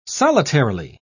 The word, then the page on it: solitarily: British English